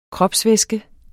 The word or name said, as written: kropsvæske